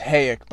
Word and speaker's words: Hayek: So it is pronounced Hayek, by the way, for those that aren't sure.